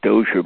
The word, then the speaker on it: Dozier: And David Dozier Books is spelled the last name D-O-Z-I-E-R.